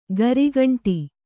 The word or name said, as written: ಗರಿಗಂಟಿ